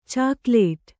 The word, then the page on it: ಚಾಕಲೆಟ್: cākaleṭ